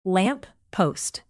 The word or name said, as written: lamp-post